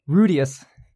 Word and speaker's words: Rudeus: Rudus Grey Rat, a newborn baby. – I think it's pronounced. – Whoa. I think it's pronounced Rudeus.